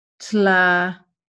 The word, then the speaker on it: Tlavatli: This changed among the second subrace, the so-called Tlavatli peoples, Tlavatli, that's spelled T-L-A-V-A-T-L-I, Tlavatli peoples.